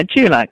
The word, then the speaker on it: Dulux: But for your company, your current company, Dulux, am I pronouncing that right? – Yeah, Dulux. Yeah, Dulux Group. Correct.